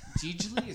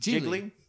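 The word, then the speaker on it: Gigli: Gigi Lee? Is that how you pronounce Gigi Lee? – It? – Jiggly. – Jiggly? I've been saying it wrong my whole life then.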